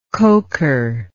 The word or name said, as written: coker